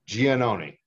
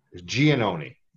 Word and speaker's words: Giannone: Giannone.